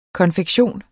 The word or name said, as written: konfektion